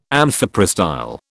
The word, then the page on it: amphiprostyle: British English